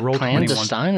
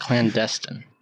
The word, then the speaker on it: clandestine: Is it clandestine or is it clandestine? – Tell whoever you want to pronounce it. – Yeah. You can say both. – Clandestine. Are they both correct? – Yes. Okay. – Mm-hmm. I don't know. – Yep. Like often and often.